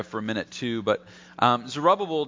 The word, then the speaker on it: Zerubbabel: The governor of them was a man by the name of Zerubbabel. Now, how would you like to have a name like that? Two B's in the middle, both pronounced separately.